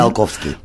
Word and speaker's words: Tsiolkovsky: Al principio de tu libro sacas una cita de Constantin, yo no sé cómo se pronuncia esto, Tsiolkovsky, ¿lo he dicho bien? – Tsiolkovsky.